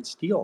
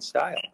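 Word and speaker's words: Steil: They think it's Brian Steele instead of Brian Stile.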